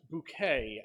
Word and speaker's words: Bucket: And this extends to insisting that her last name, which is Bucket, is actually pronounced Bouquet, and she corrects everyone who says it wrong, including her husband.